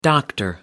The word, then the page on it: Dr. Seuss: British English